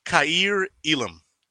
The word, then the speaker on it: Kyir Elam: And the name is pronounced Kyir Elam. Right? He pronounced it for us, but I already knew how it was pronounced. I have a cousin down the line whose name is Kyir. And it's Kai, K-A-I, ear. And he said it like the word ear in Spanish, which means to go. So Kyir Elam, like Jason Elam, the kicker. So Kyir Elam, if anybody's wondering, that's how it's pronounced.